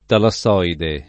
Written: talassoide

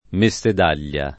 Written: Messedaglia

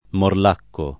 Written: morlacco

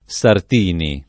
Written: Sartini

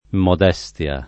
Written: modestia

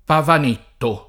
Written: Pavanetto

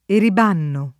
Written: eribanno

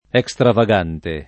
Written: extravagante

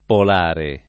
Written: polare

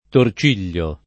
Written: torcigliare

